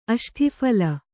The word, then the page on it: ಅಷ್ಠಿಫಲ: aṣṭhiphala